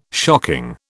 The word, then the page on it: shocking: British English